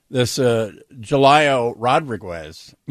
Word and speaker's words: Julio Rodriguez: This Julio Rodriguez.